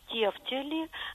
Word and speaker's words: тефтели: Галина, правильным остается то, что вы читаете в орфографическом словаре. Тефтели, гренки и жалюзи.